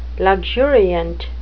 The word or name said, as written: luxuriant